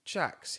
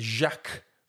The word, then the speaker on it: Jacques: And I don't like the way that he is called Jax. His name is Jacques, for fuck's sake. It's spelt Jacques. It's French.